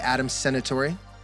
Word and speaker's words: Adam Senatori: Adam Senatori? – Nailed it.